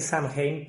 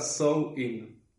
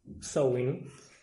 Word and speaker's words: Samhain: Se llamaba, vos la pronunciaste bien, porque yo voy a decir Samhain, pero... – Sí, se pronunciaba Sowin. – Sowin.